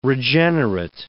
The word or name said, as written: regenerate